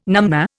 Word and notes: numnah: Indian

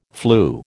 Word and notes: flu: American English

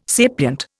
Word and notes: sapient: Indian

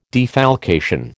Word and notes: defalcation: American English

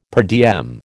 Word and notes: per diem: American English

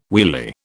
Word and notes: wheelie: British English

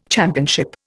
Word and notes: championship: Indian

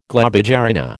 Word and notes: globigerina: American English

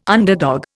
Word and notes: underdog: Indian

